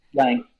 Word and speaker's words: Lange: say, Mutt Lange or Lange or however you say his name. – Lange.